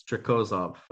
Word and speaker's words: Trikozov: It's it, it's not Trikozov or Trikozov as we call it. It's Trikozov. I've been watching him, and that's how it's been pronounced in Russian.